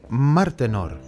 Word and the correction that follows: Martenot: Martenot, ¿no? – Martenot. – Martenot. – ¿Cómo se dice, Martenot, eso.